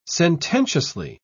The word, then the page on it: sententiously: British English